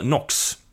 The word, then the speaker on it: Nox: Han heter sen, han kommer med i berättelsen och han heter Nox. Nox, men det är som Nox, alltså uttalas ju som knacka på han får heta det.